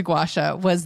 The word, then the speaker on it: gua sha: So it's two words, gua, G-U-A, sha, S-H-A. But when I hear people say it, it kind of comes out almost like one word, like gua sha.